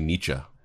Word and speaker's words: Nietzsche: I mean, that's like Friedrich Nietzsche. It's actually Nietzsche. People pronounce it wrong.